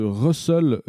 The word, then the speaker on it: Rustls: Euh, autre nouveauté, le support de Russell dans curl, italien d'Arnaud Lefebvre. Russell ou Rust, Rustls, mais c'est prononcé Russell.